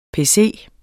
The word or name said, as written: pc